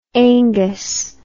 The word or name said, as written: aengus